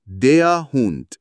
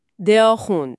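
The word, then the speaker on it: der Hund: آره بله، سگ میشه در هوند، تلفظش هست دیا هوند. – دیا هوند.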